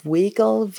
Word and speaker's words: Weigel: You know, George, I don't know how you say his name, Weigel? – Mm-hmm. Yeah.